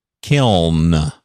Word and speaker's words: kiln: Word should not end in L-N. It's a funky pronunciation. Kiln. You just kind of feel like a fool saying it, but we had one in that room.